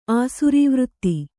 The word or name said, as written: ಆಸುರೀವೃತ್ತಿ